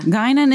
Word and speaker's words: Guinan: And that's am I saying it right? Guinan? – Yeah. – Guinan is. – You said it correctly.